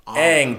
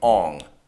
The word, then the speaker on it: Aang: They didn't even pronounce the names right. Aang became Ong.